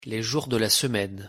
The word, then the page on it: les jours de la semaine: French